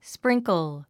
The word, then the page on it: sprinkle: spríŋkl; スプリンクゥ